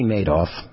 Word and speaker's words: Madoff: Maybe it's pronounced Bernie Madoff, like made, M-A-D-E, space O-F-F, but it's really M-A-D-O-F-F.